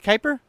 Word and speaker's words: Kuiper: Is that how it's pronounced? Kuiper? I've never seen that.